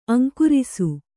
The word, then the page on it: ಅಂಗರಸ: kannada